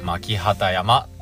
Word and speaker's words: 巻機山: チャンスの機械も同じ木ですけども、巻木山と書いて、巻畑山と呼びます。あの、名前から連想できる方もいるかもしれませんけども、この巻畑山っていうのは、え旗織りの神様ですね。